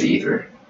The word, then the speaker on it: Aether: But as far as, like, down-tempo stuff, Emancipator, Aether, I don't know how to pronounce it. It's A-E-T-H-E-R. I think it's Aether.